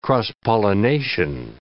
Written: cross-pollination

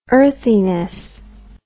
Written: earthiness

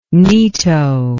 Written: neato

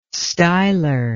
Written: styler

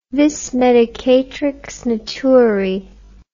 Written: vis medicatrix naturae

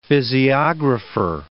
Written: physiographer

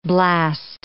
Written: blast

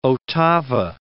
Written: ottava

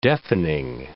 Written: deafening

